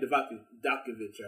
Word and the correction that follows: Dijakovic: or however you say his name. – Dominic Dijakovic. – Yeah. There you go.